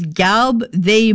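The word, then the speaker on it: Gelbvieh: So they said that the pronunciation is Galb they bulls. Okay, thank you very much. I did not know how to say that.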